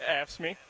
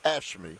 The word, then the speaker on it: AFSCME: He's with how do they pronounce that? – AFSCME. – AFSCME.